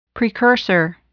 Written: precursor

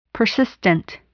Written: persistent

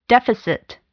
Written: deficit

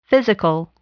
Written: physical

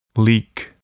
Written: leak

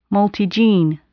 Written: multigene

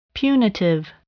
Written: punitive

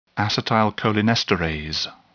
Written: acetylcholinesterase

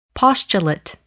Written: postulate

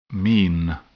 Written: mean

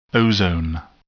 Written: ozone

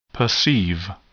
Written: perceive

